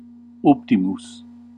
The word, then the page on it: good: /ˈɡʊd/